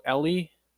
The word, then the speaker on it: Elie: Including guys like Tim Legler, who played in the NBA for 11 years, Mario Elie, I believe is how you say his last name, E-L-I-E,